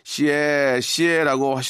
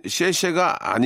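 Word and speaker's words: 시에시에 (谢谢): 마지막으로 나이 지긋하신 할머님께서 지나가실 때 아주 느리게 시에 시에라고 하시더군요. 그때 알았습니다. 중국어로 고맙습니다는 시쉐가 아니라 시에시에를 빠르게 말하는 거라는 거요.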